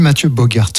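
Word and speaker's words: Mathieu Boogaerts: Et on dit Mathieu Bogart, je crois. – C'est vrai, je prononce mal, moi.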